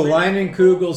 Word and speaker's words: Leinenkugel: Okay, so coming in at number 3, I don't know how to pronounce this, Leinenkugel? – Oh, Leinenkugel Summer Shandy.